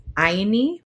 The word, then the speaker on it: Ayni: There is this term within the Quero Inca Nation that my elder uses, and it's widely used within Andean cosmovision, is Aini. You spell it A-Y-N-I.